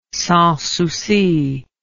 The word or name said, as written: Sans Souci